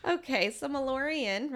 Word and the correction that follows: Meliorn: right? Did I say that correctly? Meliorn. Meliorn.